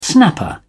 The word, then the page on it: snapper: British English